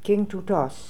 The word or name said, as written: King Too Toz